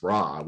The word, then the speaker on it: Ra: What you can do is you get a few words, like the name for the sun god, which normally Egyptologists and most other people would pronounce Ra, which is Ryu. – Totally wrong. Yeah, exactly. It's Ryu in Stargate.